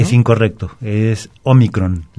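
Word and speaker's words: Ómicron: En este caso la pronunciación es Omicron. Entonces Omicron. – Lleva acento, pero en la primera O, no en la última.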